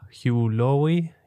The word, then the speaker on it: Hugh Laurie: gespielt vom Dr. House-Darsteller Hugh Laurie,